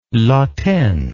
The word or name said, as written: latene